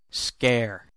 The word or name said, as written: scare